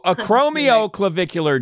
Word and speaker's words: acromioclavicular: I've been saying it wrong for years. Wait. Acromio. So acromioclavicular joint. – Yes. Acromio. It's the acromion where it articulates with the clavicle.